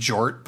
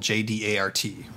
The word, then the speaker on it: J-DART: I prefer to think it's pronounced Jort, but his lawyer created an acronym called J-D-A-R-T, right? – Yeah, it's pronounced J-Dart.